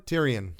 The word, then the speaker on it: Tyrion: Is this pronounced Tyrion or? – Tyrion.